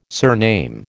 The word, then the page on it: surname: American English